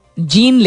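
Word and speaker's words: Jean: जीन लिखते हैं जे ई A N लेकिन इसको प्रोनाउंस करते हैं जॉन या जीन भी प्रोनाउंस करते हैं बट जीन नहीं कहते हैं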